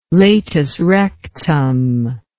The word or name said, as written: latus rectum